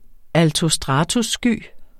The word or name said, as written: altostratussky